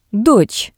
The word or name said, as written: dots